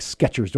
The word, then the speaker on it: Skechers: Skechers. Do I say that right? – Yep, you did it.